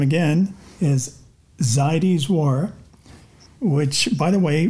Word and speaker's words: Zaidy's: Or Zaidi's. – Oh, Zaidi's, yes. – We've been saying it wrong. – However you'd like to pronounce it. When people ask, I say Zadie Smith. Are you familiar? And they say, yes. I said, you could pronounce it that way if you'd like.